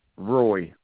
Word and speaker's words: Roy: It's spelled M-I-C-H-A-E-L. Roy. R-O-Y.